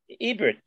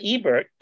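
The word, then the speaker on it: Hebert: H-E-B-E-R-T. I want to see how they pronounce it. – Number two. – Ebert. Not Ebert. Ebert.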